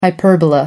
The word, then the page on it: hyperbola: American English